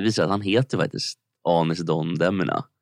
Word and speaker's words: Anis Don Demina: Så här, om man inte känner till honom, Anis Don Demina är hur det uttalas. Vi ska lyssna på de första försöken som jag ändå tyckte lät. – Klockan 8 kommer Anis Don Demina.